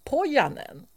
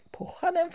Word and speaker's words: Pohjanen: Då kunde jag fråga en av våra lyssnare som skriver på vår Facebook-sida, Facebook-sida, hur vill författaren att namnet ska uttalas uppe i norr där. Och då sa han att det är som jag säger, Pohjanen fortsätter jag, men att vissa säger Pohjanen.